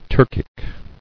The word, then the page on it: Turkic: Turk·ic